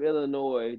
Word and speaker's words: Illinois: The S is silent. It's my home state, so get it right, Illinois.